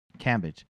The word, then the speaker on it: Cambage: Cambage.